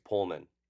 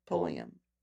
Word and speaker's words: Pulliam: Did I say that wrong? – I think it's Pullman. Keisha Knight Pullman? – No, it was Pullum. Pullum. Oh, Pulliam.